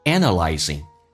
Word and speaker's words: analyze: Analyze a 这个发音比较夸张，I 不要太饱满，因为我们讲 I 属于 unstressed syllable，叫做非主重元音，所以重音放在 a 上。